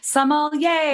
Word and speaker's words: sommelier: Yes, I always coach everyone just to say, like, think yay for wine. So sommelier. – Yay. – And you will always say it right.